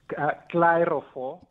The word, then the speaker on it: Clyrofor: How did I pronounce your business name? Is it correct? – Clarifor. – Clarifor. – So I really can't complain, right? – Okay, I was almost there. – Almost. – Clarifor. Okay, but it's spelled C-L-Y-R-O-F-O-R.